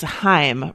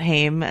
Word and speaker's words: Haim: It's Haim, right? Not Haim, as like a lot of people said.